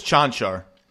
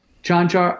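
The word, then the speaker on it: Čančar: I like – ever since I saw him in Summer League, I'm not sure I'm saying it right, Kankar. Or how do you say it? – I believe it's Chanchar. – Chanchar, I like him.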